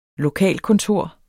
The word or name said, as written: lokalkontor